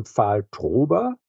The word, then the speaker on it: Pfahl-Trober: Ich heiße Armin Pfahl-Trober. Die Aussprache des Namens vermutet man nicht. Wenn man die Schreibweise des Namens kennt, ist aber halt so.